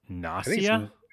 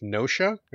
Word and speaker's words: Gnosia: I don't even know how to say this. Nausea? – I think it's Nosha. – G-N-O-S-I-A.